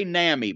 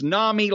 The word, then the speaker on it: NAMI: I used to say NAMI, but somebody chastened me about that. It's NAMI like mommy, except in mommy is spelled M-O-M-M-Y, whereas this is N-A-M-I.